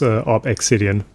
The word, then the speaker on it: ObXidian: I'm going to be pronouncing this op exidian because obvious reasons. It's an uppercase X. Uppercase letters are always pronounced individually, so it is op exidian.